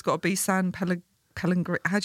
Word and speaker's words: Pellegrino: I feel like there's an extra syllable in there. – No, it's Pellegrino. – Pellegrino. – Yeah, that's exactly right. – That's how you pronounce it.